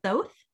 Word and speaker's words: Thoth: I said it right the first time. I don't know why I want to keep saying it like sloth. Finally, we have Thoth, Set's nephew.